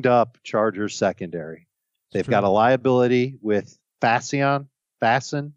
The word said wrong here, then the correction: Faison: I'm not sure how to say his last name. – Faison. – Yeah. – That'll work too.